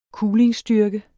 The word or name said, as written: kulingstyrke